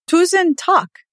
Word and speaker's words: Tusen takk: Tusen takk. Did I say that right? – Very good.